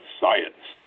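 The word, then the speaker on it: Saenz: was Mike Science. – Mm-hmm. – And that's how you pronounce it, Science.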